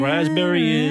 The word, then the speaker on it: raspberry: K-N-E-E-L, Neil. – Raspberry, answer. – Silent letters. – Yes, silent letters. Oh, yeah. Raspberry is... – the silent S... – P. – Well, P. Sorry. – Silent P, right.